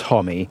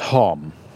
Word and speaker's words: Homme: And yes, it's pronounced Homme, not Homme, like Bronholm.